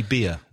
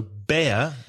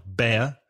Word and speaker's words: bear: P-I-G-L-E-T. – Piglet. And what about a baby bear? – That's a cub. C-U-B. – Be careful of the pronunciation of bear because a few of my students say beer, which of course is cerveza. So bear, the animal is air, like hair, stare, there, the same sound, bear.